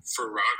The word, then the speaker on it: farrago: Let me see if I'm saying it right. But sometimes. – Am I with the drop top? – Farrago. – Oh, I did say it right.